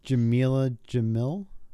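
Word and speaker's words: Jameela Jamil: So And then Jamila Jamil, is that how you say her name? – Yep.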